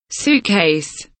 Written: suitcase

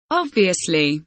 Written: obviously

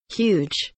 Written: huge

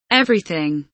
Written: everything

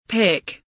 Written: pick

